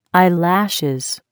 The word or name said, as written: eyelashes